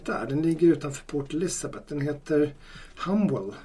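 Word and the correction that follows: Humewood: Humwood. Humwood. Humwood. Jag skulle också säga Humwood.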